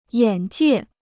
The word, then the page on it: 眼界: yǎn jiè